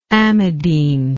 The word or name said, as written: amidine